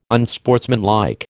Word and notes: unsportsmanlike: American English

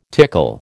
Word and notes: tickle: American English

